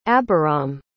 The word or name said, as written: abiram